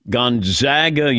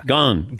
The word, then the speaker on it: Gonzaga: Gonzaga is in bag. – Yeah, you've been saying it right all along. – Gonzaga. – Gonzaga. – But not Gonzaga. It's Gonzaga. – Gone. – Gone. – It's Gonzaga. – Gonzaga. – Gone. It's in Dawn. Gone.